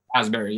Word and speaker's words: Asbury: By the way, that's correct how you say it, right? – Asbury, yeah. – Yeah, okay. I didn't want to assume there was more of a s to it or something. – Nope, you got it.